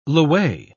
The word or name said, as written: lwei